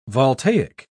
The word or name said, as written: voltaic